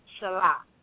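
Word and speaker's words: shalak (चालाक): C-A-L? – Yeah. Mm-hmm. So the word is spelled C as a cat, A-L-A-K-A. But it's actually pronounced shalak. – Shalak. – So, shalak. It's better to write it as C-H-A-L-A-K so that you remember how to pronounce it.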